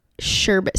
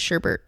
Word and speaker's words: sherbet: Sherbet. So it's sherbet, but we all pronounce it sherbert.